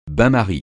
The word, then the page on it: bain-marie: francais